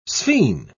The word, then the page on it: sphene: British English